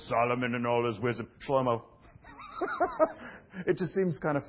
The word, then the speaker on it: Shlomo: His name, does anyone know Solomon's name, how to pronounce it in Hebrew? It's Shlomo, or sometimes as a kid, I love this name in Hebrew, Shlomo.